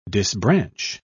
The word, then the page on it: disbranch: British English